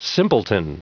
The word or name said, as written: simpleton